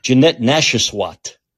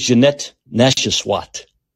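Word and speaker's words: Janette Nesheiwat: Now, I'm very angry at, I gotta say her name right, because if you don't say her last name right, you can get in trouble. Jeanette Nashaswat. You know what I mean? If you say that name wrong, I believe it's Jeanette Nashaswat.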